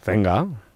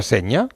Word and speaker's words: Zenga: ¿Cómo se pronuncia Walter Zenga? – Zenga. – Ah, ¿no se pronuncia Seña? – No.